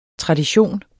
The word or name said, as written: tradition